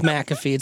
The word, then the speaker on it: McAfee: We're both McAfee. It's the only way you really pronounce the name.